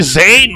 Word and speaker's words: Zane: Ja, dann haben wir noch Francis Fisher, der spielt Ruth, oder die spielt Ruth, David Bucater, ähm, Billy Sane, oder Sahn, wie man Zane. den aussprechen mag. – Sane. Boah, Christoph. – Billy Zane, Mann, Zuländer, hallo?